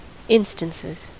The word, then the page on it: instances: American English